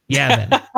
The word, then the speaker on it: Yavin: Yarvin, fuck. God damn it, Deslin. Yes, I say it wrong every time. Yavin. It's Yavin. Yavin.